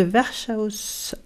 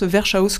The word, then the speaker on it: Verschaus: Je suis très contente, tu prononces, et vous l'avez entendu comme moi, hein, elle prononce Verschaus comme je voulais faire à l'allemande. Mais en fait, non, c'est Verschau.